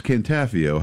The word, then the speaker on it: Cantafio: We had two people tell us how to pronounce his last name. Joel, who ran Second Chance, and Phil, who grew up with his brother, who got it wrong. – Yeah, it's Cantafio.